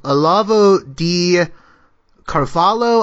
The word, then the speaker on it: Olavo de Carvalho: a man by the name of Olavo de Carvalho. I may be mispronouncing his name. I've been familiar with him. – No, that's fine.